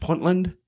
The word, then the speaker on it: Puntland: Again, this flag was from – I think I'm pronouncing it right – Puntland in Somalia, correct? – Yes, that's right.